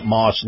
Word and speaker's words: Maus: Moss is spelled M-A-U-S.